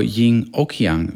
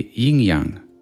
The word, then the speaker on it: yin-yang: Jag sa yin och yang, vilket är fel. Det där och tyder på att det finns två olika enheter. Men det korrekta sättet att uttala det, det är yin-yang.